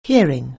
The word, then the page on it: hearing: anglais